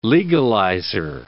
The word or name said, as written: legalizer